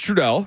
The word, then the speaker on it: Trudell: It's Trudell. Did you see how I say my name a little short there? Okay. Mm-hmm. 3, 2, 1. What's happening? It's Trudell.